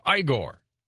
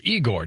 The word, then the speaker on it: Igor: Hurricane Igor, or Igor, depending on how you want to pronounce it, spelled I-G-O-R,